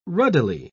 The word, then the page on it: ruddily: British English